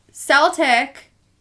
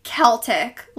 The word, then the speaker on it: Celtic: So Celtic was the early pronunciation with an S sound, and the modern pronunciation is Celtic, like with a K sound.